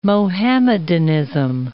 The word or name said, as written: Muhammadanism